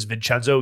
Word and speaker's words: Vincenzo: V-I-N-C-E-N-Z-O. – Correct. But most people think the C-E is se, like Vincent. They'll go Vincenzo. – Vincenzo. – No, they add an extra I at the end.